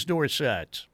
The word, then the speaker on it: Dorsett: For a long time, it was like Tony Dorsett was Tony Dorsett back at Pitt back in the day. And then he became Dorsett. And like, well, why didn't you tell us it was Dorsett?